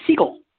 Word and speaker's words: Segall: Segal is how I pronounce it.